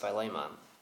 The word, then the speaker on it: Philemon: And then we'll be moving on to the book that I pronounce different every time I say it. But technically, I think it's supposed to be pronounced Philemon. And then to get y'all excited after that, we're going to be starting with 1st and 2nd Thessalonians.